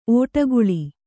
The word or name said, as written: ಒಡಕಲು